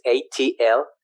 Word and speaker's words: Atlixco: It's Atlixco, Atlixco.